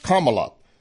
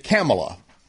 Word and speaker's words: Kamala: She says her name is pronounced Kamala. Biden pronounced it Kamala.